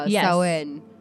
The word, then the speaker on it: Samhain: It's Samhain. It's not Samhain. It looks like Samhain, but it's not.